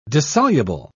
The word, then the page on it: dissoluble: British English